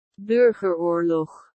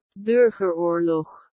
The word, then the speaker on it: Burgeroorlog: They DM'd me the way to say this word. This is apparently, this is the way that it's said. Burgerglord. Burgerglord. What? – Burgeroorlog. – That's not a...